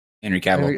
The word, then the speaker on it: Cavill: Henry Cavill.